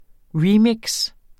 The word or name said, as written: remix